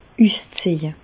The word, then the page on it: üszeje: Bas Rhin; Reichshoffen